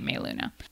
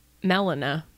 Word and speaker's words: Meluna: It might be pronounced Meluna, but I've been calling it Meluna. Meluna. – How do you know it's not Melina? – Well, great point. It's capital M, little E, capital L, U-N-A.